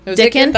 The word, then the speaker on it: Dickon: Mary Dickon. Dickon. Dickon. That's how they pronounce it in the Audible.